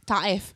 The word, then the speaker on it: Taif: Taif. It's so hard to mix the language of because there's an English pronunciation and there's. – The stress is still in the first syllable. It's just the Filipino way.